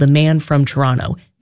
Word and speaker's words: Toronto: Everywhere else, people pronounce the second T because there is a T there. It is technically Toronto, but if you're from Toronto, you just kind of say Toronto.